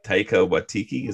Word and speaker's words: Taika Waititi: And I think that both like in Guardians of the Galaxy with James Gunn and with Thor Ragnarok with Taika Waititi, is that how you say his name? – Yeah.